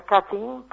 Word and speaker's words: cutting: A ver, se escribe cutting, C-U-T-T-I-N, se pronuncia cutting porque es del verbo cortar en inglés.